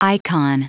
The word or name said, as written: icon